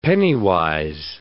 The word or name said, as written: penny-wise